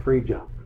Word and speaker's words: Phrygia: They all said Phrygia. I wouldn't have said that, but that's how they pronounce it.